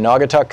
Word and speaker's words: Naugatuck: David in Nantucket, Connecticut, or Naugatuck, Connecticut. Am I saying that right, David? Oh, I'm sorry. I didn't push the right button. David in Naugatuck, Connecticut.